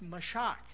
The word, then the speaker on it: mashach: And that word anoint is an Old Testament word pronounced mashach.